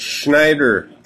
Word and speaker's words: Schneider: My name's Schneider. – How do you spell that? – Just like it sounds. – Well, is it S-C-H? Is it S-N-Y? I can't tell how it sounds. – S-C-H, Schneider.